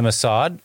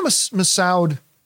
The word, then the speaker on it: Massoud: Yeah, again, the Mina Massad, or Massoud, I think is the way it's properly pronounced.